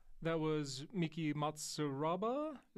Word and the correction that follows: Matsubara: Is that how you say it? Yeah, that Miki Matsubara. Sorry, Miki Matsubara.